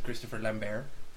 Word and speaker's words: Christopher Lambert: And it did have the genius of Christopher Lambert. I still refuse to pronounce that T at the end. It's meant to be. – Yeah, oh no, it's Christopher Lambert.